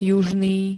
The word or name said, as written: južný